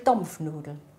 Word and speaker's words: Dampfnudeln: Dampfnudeln mit O. Statt Dampfnudeln, Dampfnudeln. – Ach so. – Mit O. Statt A sagen wir halt O.